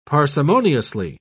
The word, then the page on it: parsimoniously: British English